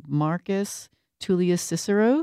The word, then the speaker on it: Marcus Tullius Cicero: And that was it from Marcus Tullius Cicero.